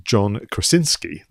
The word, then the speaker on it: John Krasinski: So you had a situation where the director, I'm going to try and pronounce his name properly now, John Krasinski, that's it, was interviewed to even do a breakdown of scenes.